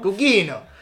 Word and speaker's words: Gugino: Gugino.